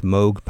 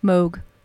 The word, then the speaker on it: Moog: Hugo Montenegro and his orchestra with Moog Power. And do you say it Moog or Moog? – Moog.